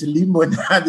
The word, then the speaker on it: Limonade: Well, it sounds like lemonade. – Yeah. – but it's Limonade, it's pronounced.